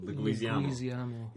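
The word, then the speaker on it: Leguizamo: How do you pronounce his surname? Luguizamo.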